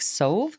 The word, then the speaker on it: Soave: I am joined today by Robby Soave. It's a fun last name because it's spelled S-O-A-V-E. So you're like Soave? Soave? But it's Soave.